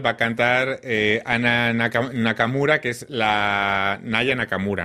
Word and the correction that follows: Aya Nakamura: ¿no? Lo he dicho bien. Aya Nakamura, perdón.